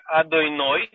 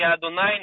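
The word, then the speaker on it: Адонай (אדני): Конкретно так, если написано тетраграмматон, он читается как на иврите, как Адойной на ашкеназском и Адонай на сефардском.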